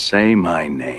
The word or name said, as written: say my name